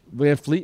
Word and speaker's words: VanVleet: And if for some reason another player has a game of his life, like, you know, Fred VanVleet? Is that how you pronounce his name? – VanVleet.